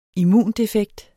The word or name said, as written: immundefekt